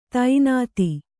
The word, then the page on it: ತಿಣ್ಣಿತು: tinnitu